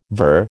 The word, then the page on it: voyeur: American English